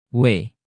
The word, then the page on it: w: Indonesian